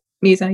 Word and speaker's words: Musa: You're wonderful in that as Musa. Is it? Mm-hmm. Am I saying her name right? – Yeah. – All right, cool. – Musa, yeah.